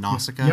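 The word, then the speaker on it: Nausicaä: So Nausicaa, right? Did I say it right, Sean? Nausicaa? – Yep, I think so.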